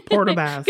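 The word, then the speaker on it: Port aux Basques: So you're out in St. John's, Newfoundland, but you grew up in, I don't want to say it wrong, Port-au-Basque, yeah. – Port-au-Basque. – Good job. – What's your experience with virtual care been? – Yeah, sure. So growing up in a rural community like Port-au-Basque, before COVID, there wasn't a lot of virtual care around.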